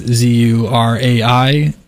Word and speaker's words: Zuraitis: I believe I'm saying that correctly. Nicole Zuraitis. It is spelt Zuraitis. That is Zuraitis.